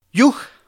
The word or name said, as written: yuja